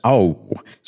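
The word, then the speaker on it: á: Today is í dag, and au, which is the word for on, is simply the letter A with an accent over it. It's pronounced au.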